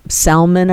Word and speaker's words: Salmon: You know, Salmon, I guess, is that how you say his name? Mm-hmm.